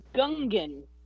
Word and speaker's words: Gungan: or D, the Gungan? – Correct.